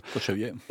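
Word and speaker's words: Kočevje: Il s'agit de la capture de Pyros dans la réserve de la région de Kozevje. J'espère que je prononce bien le nom de cette réserve. – Kozevje. – Au moment, voilà, c'est beaucoup mieux en slovène, au moment de sa réintroduction dans les Pyrénées.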